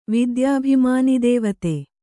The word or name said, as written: ವಿದ್ಯಾಭಿಮಾನಿದೇವತೆ